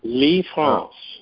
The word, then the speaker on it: Lisfranc: L-I-S-F-R-A-N-C. – Lefrance. – So this is called a Lefrance fracture.